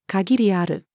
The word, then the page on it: 限りある: female